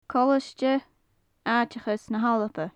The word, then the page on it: colaisde aiteachais na h-alba: gaelic